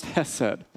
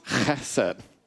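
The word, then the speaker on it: chesed (חסד): Okay, it's not pronounced hesed, okay? It is pronounced chesed. Hebrew alphabet has two different H sounds. There's the soft H, the he, and the hard H, the chet.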